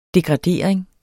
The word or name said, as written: degradering